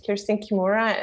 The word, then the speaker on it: Kersten Kimura: So my Instagram is my first and last name, Kersten Kimura, and Kersten is spelled K-E-R-S-T-E-N, K-I-M-U-R-A.